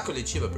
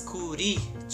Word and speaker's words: Curitiba: It's not Curitiba, Bridget. It's Curitiba.